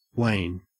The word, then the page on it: wane: Australian English